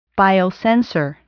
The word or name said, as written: biosensor